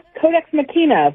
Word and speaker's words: Codex Machina: Her upcoming book is called Codex Machina. How am I pronouncing that, Heather? Pretty good? – Codex Machina, but I'll take it either way.